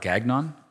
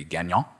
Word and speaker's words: Gagnon: There's a book by a guy named Robert Gagnon, who, and that is how you pronounce his name because he's American, not Canadian. It would be Gagnon, right?